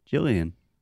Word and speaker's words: Gillian: Her name is Gillian Welch, but I also hear people pronounce it Jillian.